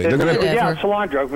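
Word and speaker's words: Solyndra: Solandra, because it's almost like you and I would use cilantro.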